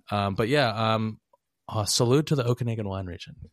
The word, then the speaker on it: Okanagan: Stay strong, Okanoga. Is that how it's pronounced? – Okanagan. – Okanagan sounds very like, let me go get my toboggan.